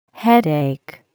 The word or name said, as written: headache